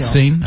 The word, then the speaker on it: Seine: 好，但我也不知道这个塞纳河应该怎么读啊。当年我们就读成 Seine 了，就 Seine.